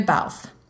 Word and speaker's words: Balfe: So officially, our Claire's, her name is actually Katrina Balfe. – Yes, I believe it is for real Katrina Balfe. – Like Alf with a B on the front.